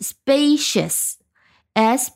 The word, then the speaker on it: spacious: i o u s 变成 spacious 啊，这个 c 它的读音因为加上了 i 也变掉了，变成了 sh，而不是 s，所以我们读起来是 spacious，s p a c i o u s，意思是空间特别大的。